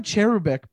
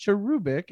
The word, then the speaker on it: cherubic: And I go Cherubic, but I know it's pronounced Cherubic. It's supposed to be like emphasis on the ooh.